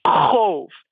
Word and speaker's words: qov: Oh, by the way, your Klingon name is spelled Q-O-V. How do you pronounce that? – It's going to sound like a wind noise again. cove. I say it rhymes with stove, except the first sound is like you're choking on spinach.